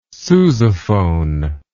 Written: sousaphone

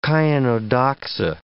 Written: chionodoxa

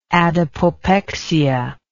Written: adipopexia

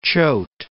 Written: Choate

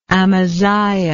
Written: amaziah

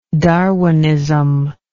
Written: Darwinistic

